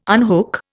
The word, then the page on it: unhook: Indian